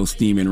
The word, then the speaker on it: Steeman: Oh, man, a 52-year-old Maryland man named Thomas Steeman. And just know Steeman rhymes with semen for a reason.